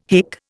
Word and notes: hick: Indian

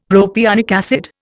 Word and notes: propionic acid: Indian

